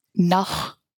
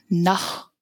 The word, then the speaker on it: naxw: it's nach. So the English spelling would be N-A-X-W, nach. – N-A-X-W.